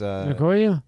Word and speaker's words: Ngannou: Francis Ngannou.